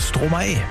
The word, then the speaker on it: Stromae: Hab den mir letzte Woche geschrieben, hab gesagt, Mensch, der hat doch einfach Maestro umgedreht, die Silben, und deswegen heißt der Stromae.